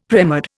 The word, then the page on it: premed: Indian